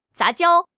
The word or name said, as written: 杂交